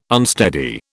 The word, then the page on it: unsteady: British English